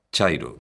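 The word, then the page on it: chairo: Galician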